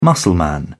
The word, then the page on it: muscleman: British English